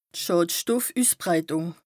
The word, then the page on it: Schàdstoffüssbreitùng: Bas Rhin